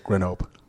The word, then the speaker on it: Grenoble: I like to pretend I can speak French, so I say Grenoble. – you don't pronounce the L and the E.